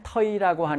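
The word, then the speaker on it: thầy: 자, 요번엔 터이라고 하는 단어가 나왔어요. 터이. 자, A에 이렇게 모자가 있는 게 어발음이라고 그랬지? 그래서 터가 되는 거야. 이. 내려가는 성조, 터이가 돼.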